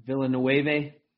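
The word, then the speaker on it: Villeneuve: I think, you know, Dennis Vanilla, I'm going to say his name wrong, Villanueva.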